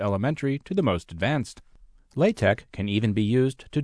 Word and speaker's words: LaTeX: LaTeX, pronounced LaTeX or alternatively, La as in lava and Tech as in technology, was initially invented as a typesetting language for mathematical notation.